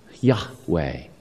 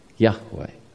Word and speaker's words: Yahweh: It's pronounced Yahweh, Yahweh.